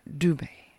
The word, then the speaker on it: Dube: And it asks the question, what is the connection to South Africa's Nokutela Medima Dube? I put the accent on the wrong part. Dube.